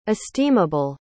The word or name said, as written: esteemable